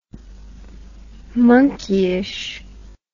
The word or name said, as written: monkeyish